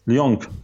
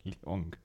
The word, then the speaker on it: Lyon: Muss man das G am Ende mit aussprechen? Lyon? – Lyon. – Lyon, ja.